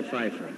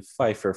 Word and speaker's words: Pfeiffer: Hello, everyone, and welcome to Pfeiffer Fridays, where we walk you through the films of one Michelle Pfeiffer, and every F word automatically has a silent P.